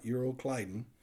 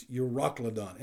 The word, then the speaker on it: Euroclydon: That's not Euroclydon. It's Euroclydon.